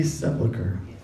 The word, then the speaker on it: sepulcher: sepulcher. That word is not pronounced how it looks.